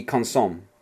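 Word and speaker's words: consommé: I figured, I mean, the pronunciation I would go for would be consomme.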